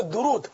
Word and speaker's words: درود: صحیح تلفظ ہے درود, دال پر بھی پیش را پر بھی پیش, درود درود پاک درود شریف.